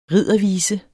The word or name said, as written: riddervise